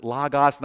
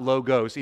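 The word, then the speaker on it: logos (λόγος): and so I have to tell you that logos is two omicrons, not two omegas in the Greek language, and so it's logos, not logos, even though everyone, particularly in America, calls it logos, and it drives me crazy.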